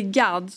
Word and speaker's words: Gad: Nej det är Gad så uttalas i alla, fall, i alla fall mitt.